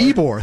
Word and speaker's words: Ybor: And we'll be joined by Bill Radke from Ybor. Am I saying that right? Do you remember? – I believe, yeah, it's Ybor. – Ybor, thank you.